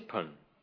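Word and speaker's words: Bön: It's spelt usually, by the way, B-O-N, with two little dots side by side above the O. Sometimes it's spelled not with an initial B, but an initial P. But the actual sound is halfway between B and P. So it's pronounced in Tibetan Pun.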